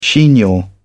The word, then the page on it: chignon: British English